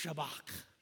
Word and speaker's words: Shabak: Say that with me, Shabak. The way you say that properly in the Hebrew is you have to pretend like there's a kernel of popcorn in the back of your throat, which I wouldn't do if you don't want to give the person a shower in front of you right now.